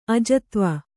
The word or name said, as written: ಅಜತ್ವ